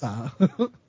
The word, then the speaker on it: La Puente: When we had to live in California and you would say things like, La Puente, Hills. La Puente.